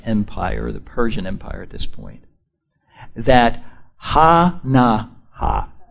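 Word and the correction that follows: Hanani: Let me say it again. I got them all spelled out here phonetically. Ha-ne-nai. Say that with me. Ha-ne-nai.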